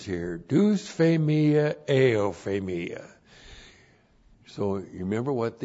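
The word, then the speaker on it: dusphemia: D-U-S-P-H, long E-M-I-A, deus phemia.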